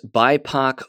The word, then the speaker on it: BIPOC: Here's the next one, and I mispronounced it earlier, but it's BIPOC folks, is I guess how it's pronounced.